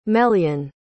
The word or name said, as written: melyon